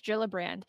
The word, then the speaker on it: Gillibrand: What's the correct pronunciation? – I believe it's Gillibrand.